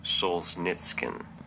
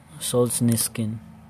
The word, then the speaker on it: Solzhenitsyn: How to pronounce Alexander Solzhenitsyn? – Alexander Solzhenitsyn. – Solzhenitsyn. Solzhenitsyn. Okay.